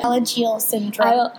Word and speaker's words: Alagille syndrome: Alagille syndrome.